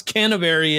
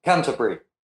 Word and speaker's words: Canterbury: How would you pronounce Canterbury in the UK? – Yep. Canterbury. – Canterbury. There you go.